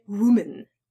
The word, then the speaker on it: woman: Most Poles mispronounce the words woman and women. So let's make it clear. When you have one female, that's a woman. Woman.